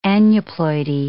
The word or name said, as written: aneuploidy